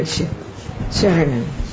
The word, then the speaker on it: saranam: Saranam in Thai. Yeah. But you pronounce as a Charanam. – Charanam. – Charanam. We use the NG and use M. Yeah.